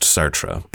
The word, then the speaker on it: Sartre: I finally figured out by the end of the semester that it's pronounced Sartre, but I'm going to leave this in because this is just where I was at the time.